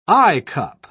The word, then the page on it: eyecup: British English